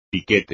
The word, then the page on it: piquete: espagnol